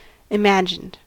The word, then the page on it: imagined: American English